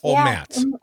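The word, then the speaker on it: O'Matz: And am I saying that right, Megan? – Omatz.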